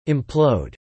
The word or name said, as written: implode